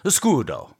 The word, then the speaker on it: escudo: All players will participate in a silent auction using their supply of game money called escudo. You have to pronounce it with the Italian. Escudo.